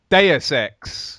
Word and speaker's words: Deus Ex: Is it Deus Ex? – Deus Ex.